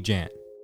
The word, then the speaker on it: Jan: My name is Jan. Technically it's pronounced John, but you can call me Jan. Like January, but without the wary.